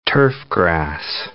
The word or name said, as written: turfgrass